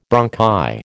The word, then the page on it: bronchi: American English